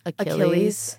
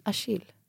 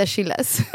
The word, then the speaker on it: Achille: I think hans nickname can be chill because his name's Achilles and then it's spelled CH. – A chill. – På franska säger man Achille. – Achilles. – Hur vackert. – Jättefint. – Nej, man säger inte Achilles.